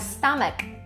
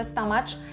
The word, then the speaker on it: stomach: Wyraz stamek, stamek mamy na końcu CH, ale broń Boże stamacz, będziemy mówić stamek oraz ból to jest ache, a nie age, więc stomach ache, ból brzucha, albo też headache to ból głowy.